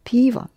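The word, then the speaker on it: piva (пиво): And piva is also neuter. Or is unstressed in the ending, piva.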